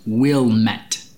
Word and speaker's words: Willamette: It's spelled Willamette. – Yeah. – But they say it's pronounced Willmette, kind of like a three-syllable word down into two. So Willmette. – Yeah. And you're kind of supposed to slur the will, so it's almost like wool, right?